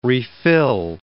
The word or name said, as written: refill